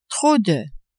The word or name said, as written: trop de